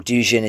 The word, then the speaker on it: Dugin: The first being that I think it gives Alexander Dugin, or Dugin as he's sometimes pronounced, too much credit into his significance, as I talked about in the podcast before.